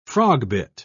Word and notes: frogbit: British English

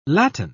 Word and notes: latten: British English

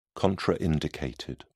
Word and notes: contraindicated: British English

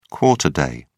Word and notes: quarter day: British English

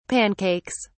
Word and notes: pancakes: British English

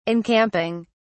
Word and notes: encamping: British English

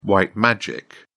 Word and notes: white magic: British English

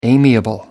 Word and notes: amiable: American English

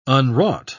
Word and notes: unwrought: British English

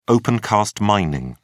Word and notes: opencast mining: British English

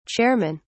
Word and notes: chairmen: British English

